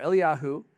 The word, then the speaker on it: Eliyahu: Elijah or Eliyahu was, that's the Hebrew pronunciation, he was the great prophet.